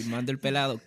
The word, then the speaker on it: Mando El Pelado: Mando El Pelado.